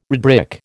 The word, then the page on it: redbrick: American English